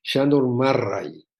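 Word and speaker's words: Sándor Márai: Sí, bueno, mencionaste a dos que son, yo creo que temas de estudio, los mencionó Goldenberg, el de eh, una paciente húngara me dijo que se pronuncia Shandor Marray, así me dijo que se decía.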